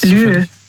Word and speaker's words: lieu: Lieu. Lieu. – Lü? – Also Lü, das schreibt man, also L-I-E und dann U.